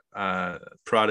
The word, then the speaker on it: Praught: A lot of pressure there, but you can follow me on Twitter at my name, Ryan Pratt. Pratt is spelled P-R-A-U-G-H-T.